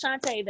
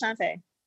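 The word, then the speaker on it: Shanté: Like, my middle name is Shantae, and it's C-S. H-A-N-T-E with an apostrophe over the E. I was like, really, Mom? People be like, see Shantae? No, it's just Shantae. The S is silent.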